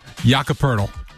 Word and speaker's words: Poeltl: Am I saying this right? Poeltl? Poello? I always butcher his name. Is that right? How do you say? I can't even hear you. – Jakob Poeltl. – Thank you. Jakob Poeltl. Poeltl. It's spelled P-O-E-L-T-L.